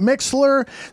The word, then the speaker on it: MixLR: Some people pronounce it Mixler.